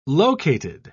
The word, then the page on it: located: British English